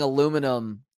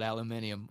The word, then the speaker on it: aluminium: It's aluminium. Aluminum. Where's the O's in there? There's no O in there. Lou? Where do you get Lou from?